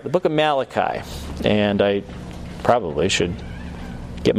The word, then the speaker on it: Malachi: So, all right, we'll get into Malachi. That's how you're supposed to pronounce it.